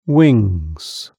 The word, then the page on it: wings: British English